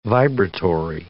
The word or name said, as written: vibratory